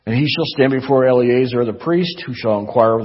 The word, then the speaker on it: Eleazar: Set before him Eleazar, I can't pronounce that, Eleazar, thank you, the priest, and before all the congregation, and inaugurate him in their sight.